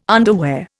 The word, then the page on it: underwear: Indian